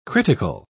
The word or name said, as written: critical